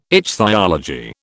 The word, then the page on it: ichthyology: British English